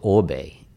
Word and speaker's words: Oribe: Now, in the hair world, we'll call it Orbe. But do you? – It's spelt quite weird, isn't it? – It's spelled O-R-I-B-E. – Yes. So Areeb. – It's spelled Areeb. – Yeah. – But Orbe. – But certainly a lot of people call it Orbe, yeah.